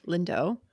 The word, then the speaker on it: Lindo: Ephemera by podcast favorite, one of our first interviews, MJ Lindo, rhymes with window.